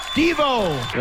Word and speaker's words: Devo: I said, Marty pronounces it DeVoe. – Yeah. – And she lost her mind. – Right. – She went, send him to a Devo concert.